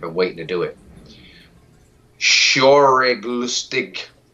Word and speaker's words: Schauriglustig: Schauriglustig. Now, let me spell it for you so you know I'm actually saying it the way this shit is spelled. S-C-H-A-U-R-I-G-L-U-S-T-I-G.